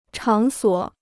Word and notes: 场所: chǎng suǒ

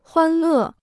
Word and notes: 欢乐: huān lè

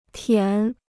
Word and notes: 舔: tiǎn

